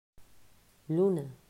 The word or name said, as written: Luna